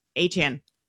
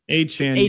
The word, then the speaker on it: Achane: I get the two brothers mixed up, or is it Travis Achane? I got to get the name right. – Achane now. Achane, you Achane. – changed it, yeah.